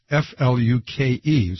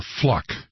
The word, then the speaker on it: Fluke: And her name is Fluke. So obviously the E on the end makes it a long U. – Mm-hmm. – And he kept calling her Fluck.